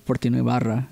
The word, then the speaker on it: Fortino Ibarra: Maybe it's because I said my name too Mexican when they asked me what my name was. – Oh, how do you say it? – Like what's supposed to be said. You know, Fortino Ibarra.